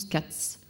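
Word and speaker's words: Kac: Euh, il s'agit d'une œuvre du Brésilien Eduardo Cac, ça se prononce Katz.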